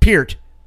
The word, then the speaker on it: Peart: It's Peart and it was Rush. I will correct you on that one. – I know. – No, I'm saying, the pronunciation. Peart.